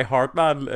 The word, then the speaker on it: Hardman: He himself uses only two syllables. It's Hardman. And that's what Cliff calls him as well.